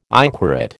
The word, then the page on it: inquorate: American English